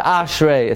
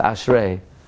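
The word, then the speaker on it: Ashrei (אשרי): It's not Ashrei, it's Ashrei.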